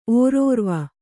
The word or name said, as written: ಓರೋರ್ವ